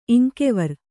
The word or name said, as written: ಇಂಕೆವರ್